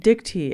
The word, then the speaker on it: Dictee: Dicty. It's spelled as spelled like spelled. It's spelled D-I-C-T-E-E.